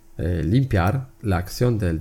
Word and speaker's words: limpiar: El limpiar es una palabra aguda de dos sílabas. Las palabras agudas son aquellas acentuadas en la última sílaba.